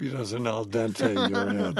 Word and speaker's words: al dente: Every time he pronounces it El Dante.